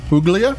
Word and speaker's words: Puglia: The first thing, Puglia is spelled P-U-G-L-I-A, which sounds like Puglia, but it's Puglia. G is silent whenever times there's a G-L. And then we go to the Aglianico, and that's A-G-L-I-A-N-I-C-O.